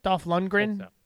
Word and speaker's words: Dolph Lundgren: but it highlights Dolph Lundgren. Is that how you say his name? Dolph Lundgren? – Yeah.